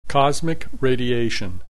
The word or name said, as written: cosmic radiation